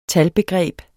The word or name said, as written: talbegreb